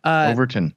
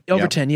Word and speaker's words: Overton: I don't know how to pronounce that. – Overton. – Overton. Yeah. There you go.